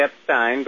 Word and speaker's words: Epstein: Well, I say Epstein, but Epstein, I'm sorry. – Either way is okay. – Okay, Epstein. – Just think Einstein with an F.